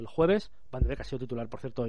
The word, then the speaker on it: Van de Beek: Es Van de Beek. – Van de Beek.